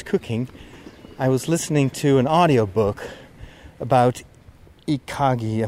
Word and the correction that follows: Ikigai: Am I saying that correctly? It's this Japanese uh, art of living and working. Let me go double check that. I've already reviewed part of the book Ikigai.